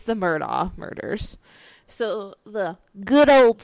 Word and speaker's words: Murdaugh: Murdaugh, yeah.